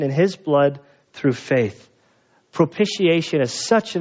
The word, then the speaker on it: propitiation: It took me like 12 years to say it right. It's like, what is it? Propitiation. And it simply means satisfied.